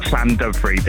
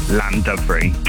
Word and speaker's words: Llandovery: Playing today is Llandovery. Have I pronounced that right? – Nearly, yeah. It's Llandovery. If I was to say it to, like, English people, then it's Llandovery. Drop the first L.